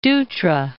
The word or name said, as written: Dutra